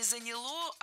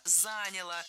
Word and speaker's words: заняло: Нет, плохо, потому что не заняло, а заняло. Ударение не ставим на окончание ни в коем случае.